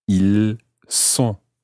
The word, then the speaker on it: ils sont: So that time it's an S sound, a S. Ils sont. – Ils sont. – So in that situation, you're not pronouncing the S of the il, but you're pronouncing the S of the word sont, S-O-N-T.